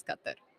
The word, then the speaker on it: Qatar: So it's Qatar? – Qatar. – Yeah, but it's mostly the white version of it is Qatar. – Qatar, right. – But they the cultural pronunciation is Qatar.